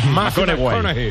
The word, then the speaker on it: McConaughey: Mate McConaughey. – Sí, sí, sí, sí. – Hay que ir corrigiendo la pronunciación. – McConaughey.